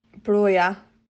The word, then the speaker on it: Broja: For the avoidance of doubt, this is how you say Armando Broja / Brozier, according to a natural Albanian. – Broja.